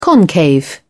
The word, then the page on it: concave: British English